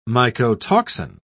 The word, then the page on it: mycotoxin: British English